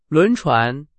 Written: 轮船

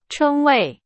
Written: 称为

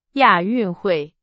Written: 亚运会

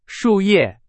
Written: 树叶

